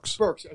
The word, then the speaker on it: Birx: Birx.